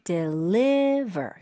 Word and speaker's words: deliver: D-E-L-I. 그다음 E가 안 들어갑니다. L-I-V-E-R. – 맞아요. 그리고 right. 강세는 deliver.